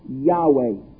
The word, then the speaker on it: Yahweh: It was spelled by the consonants YHWH. We think that it is pronounced Yahweh.